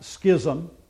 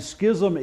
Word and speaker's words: schism: And for what it's worth, the Greek word is pronounced schism, and schism is the second pronunciation in the dictionary. But in English, it's schism.